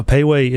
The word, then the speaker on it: Pei Wei: No, Payway is spelled P-E-I-W-E-I.